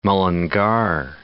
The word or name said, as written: Mullingar